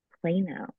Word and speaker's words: Plano: A group practice of functional nutritionists and health coaches based out of Plano. Am I saying that right? Texas? – Plano.